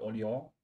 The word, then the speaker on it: Orléans: Da vielleicht ein kleiner Einschub zur Betonung wieder Orléans und nicht Orleans.